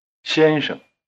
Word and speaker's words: 先生: The second syllable of 先生 begins with the same consonant as 是 and 谁, which you make with the tongue curled back. Try repeating Mr. 先生, 先生, 先生. – Your tongue has to shift gears, so to speak, between the two syllables. It's forward for the first syllable, 先, and retracted for the second, 生.